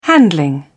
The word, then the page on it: handling: British English